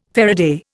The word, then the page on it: faraday: Indian